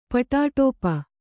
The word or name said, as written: ಪಟಾಟೋಪ